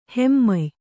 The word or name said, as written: ಹಿಮ್ಮೆಯ್